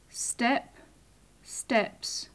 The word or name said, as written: step-steps